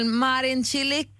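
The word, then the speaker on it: Marin Čilić: Okej, okay, kanske lite otydligt, men så där lät det i alla fall förra veckan El Marin Cilic Säger man ja, så?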